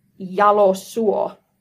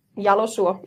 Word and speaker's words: Jalosuo: Yeah, that was pretty bad. So you say it Jalosuo. – Jalosuo. So I have Jalosuo. Jalosuo. – Jalosuo.